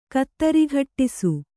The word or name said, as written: ಕತ್ತರಿಘಟ್ಟಿಸು